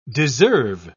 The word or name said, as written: deserve